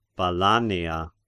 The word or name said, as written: Balanea